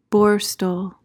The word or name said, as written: borstal